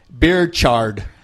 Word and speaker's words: Birchard: We're going with Rutherford Britcher Hayes. – I'm sorry, I mispronounced it. It's Beerchard. – Oh, Beerchard. – B-I-R-C-H-A-R-D.